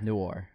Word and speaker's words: noir: Congratulations on pronouncing Noir. – I took my time with it. I took my time. – Noir. – Noir. – There you go, man.